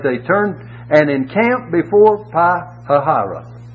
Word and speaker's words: Pi-hahiroth: You have to pronounce that in four syllables. It's Pi-hahiroth, between Baal-zephon.